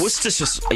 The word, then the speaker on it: Worcestershire: Worcestershire. Yeah, I struggle with that one as well. – Worcester, like in Worcester in the Cape, yeah. And then sure. But put them together, Worcestershire sauce.